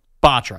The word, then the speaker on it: Botcher: Botcher. – I said Betcher because it's spelled like James Betcher, the old defensive coordinator.